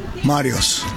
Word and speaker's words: Marios: And one of those people, Mario, tell me your Greek name. How do you say it? – Marios.